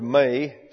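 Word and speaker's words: may (μή): It's simply the word may, spelled M-E. The E there is an eta. It's a long E pronounced as an English long A.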